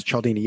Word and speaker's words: Cialdini: And then he kind of points you to Cialdini, if I'm saying that right. – Yes, Cialdini, yes.